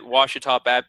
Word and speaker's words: Ouachita: So I can see that since 2011, you've been the head coach at Ouachita Baptist University.